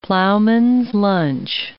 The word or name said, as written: ploughman's lunch